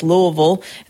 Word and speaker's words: Louisville: Some of you might say Louisville, but at least in my section of the South, it's Louisville. And that could even be like one syllable, Louisville. I don't even know if you could break it up.